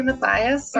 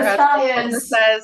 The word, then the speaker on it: Mattheis: Actually, Mattheis, or Mattheis. – As my partner says, a very bad American pronunciation of a good German name.